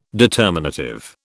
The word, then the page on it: determinative: British English